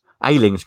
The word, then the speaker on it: Ayling: Ayling's good. Is it Ayling? Have I said that right? Yeah, yeah.